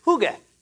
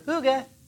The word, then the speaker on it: hygge: And the word is hygge. And there's lots of different ways to pronounce it. H-Y-G-G-E, hygge, hygge.